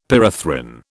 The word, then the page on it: pyrethrin: British English